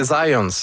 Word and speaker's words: Zajonc: Non, c'est Zajonc. – Ouais, OK. – J'ai-tu pas dit Zajonc? – Vous avez dit plus Zajonc, mais. – Parce que j'ai prononcé un petit peu à l'anglais. J'ai prononcé mon polonais à l'anglais.